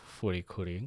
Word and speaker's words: FLCL: Anyway, I'll stick with furikuri because that's the most common pronunciation I've heard, again, especially from you and a lot of our friends who've watched the show.